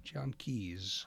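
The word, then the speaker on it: Gian Keys: I want to be able to pronounce his name correctly. Jian Keys. I really hope I didn't mispronounce his name. G-I-A-N. Jian Keys.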